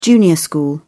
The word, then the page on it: junior school: British English